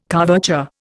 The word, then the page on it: couverture: Indian